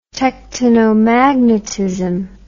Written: tectonomagnetism

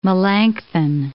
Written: Melanchthon

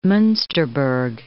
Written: Munsterberg

